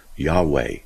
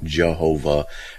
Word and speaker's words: Yahweh: The word Jehovah is just an Englishized, a very loose pronunciation of what's called a tetragrammaton. Yod-Heh-Vod-Heh, or YHWH, Yahweh, and Yahweh. They don't have a J sound, but someone somehow wrote Jehovah, or J-H-W-H, something like that, and it became Jehovah. That's all it is. It's just a very loose translation or mispronunciation of the original.